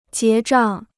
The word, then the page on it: 结账: jié zhàng